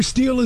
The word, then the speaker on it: STIHL: It is steel, S-T-I-H-L, pronounced steel, the chainsaw company.